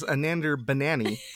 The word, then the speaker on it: Anander Banani: I thought you said that her name was like Anander Banani, and I was like, wait, what? – I will now refer to her as Anander Banani because my wife has corrected my pronunciation.